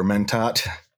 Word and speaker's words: Mentat: The Mentats or Mentat.